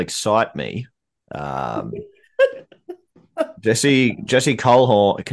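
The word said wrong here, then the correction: Colquhoun: Is it Colhoun? Okay, Colhoun, sorry.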